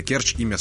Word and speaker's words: Керчи: Если вы заглянете в словарь русского языка, ну вот вы увидите там, и я надеюсь, наконец-то запомните, что когда а, говорится слово Керчи, то ударение ставится именно на первый слог. Это и есть единственная возможная литературная норма. Множество жителей Керчи говорит Керчи, но это разговорная норма.